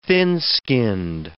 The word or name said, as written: thin-skinned